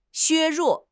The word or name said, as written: 削弱